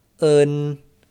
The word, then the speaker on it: en: You can add an N to the end of the sound to get un. Be careful not to pronounce en the English way, like n. – Or the Spanish way, en, because pinyin en is a unique sound, un.